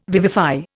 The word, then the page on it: vivify: Indian